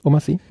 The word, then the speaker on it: Omar Sy: Ja, Omar Sy.